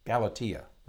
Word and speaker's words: Galatea: And he actually named her Galatea. Galatea is how they pronounce it.